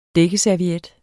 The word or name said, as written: dækkeserviet